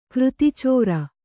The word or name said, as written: ಕೃತಿಚೋರ